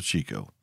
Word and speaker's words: Chicot: But speaking of Chico, it's spelled C-H-I-C-O-T. And here's a pro tip for you. If you ever find yourself working undercover in Arkansas, the quickest way to let folks know you ain't from the natural state is to pronounce Chico as Chicot, El Dorado as El Dorado, and Nevada as Nevada.